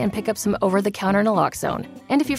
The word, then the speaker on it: Naloxone: For the record, it's Naloxone. Nuh, like, is Naloxone addictive? Nuh-uh. Lox, like, Naloxone keeps humans safe, like locks keep bikes safe.